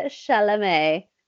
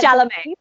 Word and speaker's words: Chalamet: Okay, I might need your help pronouncing his last name because it's French. So I got Timothy Chalamet. Is that his name? – Yeah. Timothy Chalamet. Okay, we got Chalamet. Oh, okay, yeah.